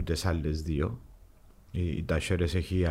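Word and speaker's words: Talleres: Άρα είναι ε, τασέρε. Έτσι, έτσι, έτσι, την προφέρουν οι Αργεντίνοι. Ο Ισπανό προφέρει την άλλη.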